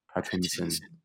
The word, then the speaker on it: Pattinson: I have to say the I. I have to give emphasis on the I. Mm-hmm. So therefore, he will be known as Robert Pattinson.